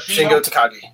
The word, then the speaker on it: Shingo Takagi: They went 4-1, and I believe the Shingo Takagi. Did I say that right? – Yep, Shingo Takagi. You're right.